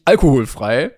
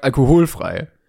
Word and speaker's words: alkoholfrei: Und die Betonung des Wortes Alkohol ist in beiden Fällen unterschiedlich. Also man sagt Alkohol, stimmt. Aber man sagt nicht alkoholfrei, sondern man sagt alkoholfrei.